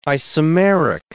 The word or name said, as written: isomeric